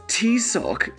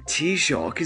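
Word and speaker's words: Taoiseach: Poor Liz tried to say the Irish word for Prime Minister, but she pronounced it as T-Sock and all hell broke loose. You had all these paddies mocking her for her pronunciation, even though T-Shock is the only Irish word most of you know,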